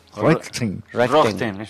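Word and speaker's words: Röntgen: Roentgen.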